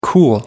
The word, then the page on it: cool: American English